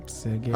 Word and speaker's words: Sergey: Du musst aufpassen, wie du Sergey aussprichst. – Dass ich nicht zu gay ausspreche? – Nee, genau. – Nicht gay so betone, meinst du? – Sergey.